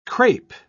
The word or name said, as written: crape